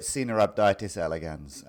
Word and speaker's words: Caenorhabditis elegans: So in terms of the pronunciation, I've heard different pronunciations. I'm not an expert on Latin, but I call it Caenorhabditis elegans. And a lot of people I know, I learned it from them.